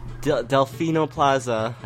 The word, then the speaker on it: Delfino Plaza: Delfino Plaza, I pronounced it right this time.